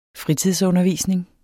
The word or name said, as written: fritidsundervisning